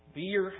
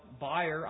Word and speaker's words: bier: As some translations say, beer, however you want to pronounce it, B-I-E-R, buyer, I would say, which just means coffin.